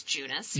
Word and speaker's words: Junas: It's J U N A P R, and it's a play on my maiden name, which is Junas, yeah.